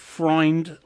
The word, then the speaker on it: Freund: if my name is Freund in German, that's an oi sound. Freund, like uh-huh, Sigmund Freud. EU is always pronounced.